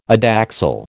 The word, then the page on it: adaxial: American English